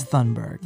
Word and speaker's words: Thunberg: I really don't know how to say it. I think it's Thunberg.